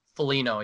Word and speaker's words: Foligno: And see, every single player actually on our team like has like the potential, I want to say, besides Marcus Foligno, or how do you say his name? Foligno? – Foligno, yeah.